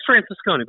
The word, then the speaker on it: Francescone: You're close enough. It's Francescone.